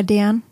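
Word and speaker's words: Deern: Spricht man so aus, Dern? – Hamburger Dern. Dern, ja.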